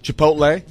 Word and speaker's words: Chipotle: Pronounce the L. – Chipotle. – Chipotle.